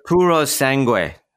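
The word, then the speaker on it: Purosangue: It's the Puro Sangue. It's the Puro Sangue. Beautiful. That's the Italian pronunciation that I would use.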